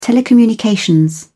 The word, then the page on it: telecommunications: British English